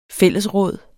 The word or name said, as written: fællesråd